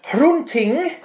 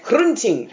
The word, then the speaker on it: Hrunting: Hrunting. – Hrunting. – Thank you very much. Hrunting.